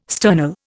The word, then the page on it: sternal: Indian